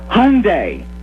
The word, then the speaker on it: Hyundai: This is the Hyundai Tournament of Champions. Okay, am I pronouncing the name right? – You nailed it.